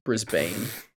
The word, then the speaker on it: Brisbane: Did you say Brisbane? – Is that what it's Brisbane. – Is it Brisbane? Melbourne. Brisbane. Brisbane. Sorry.